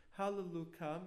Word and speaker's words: Halleluka: Halleluka means praise God. Now, we're not allowed to pronounce it with a Y, because that would be God's name. So outside of prayer, we always pronounce it as Halleluka.